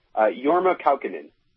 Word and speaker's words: Jorma Kaukonen: Jorma Kaukonen.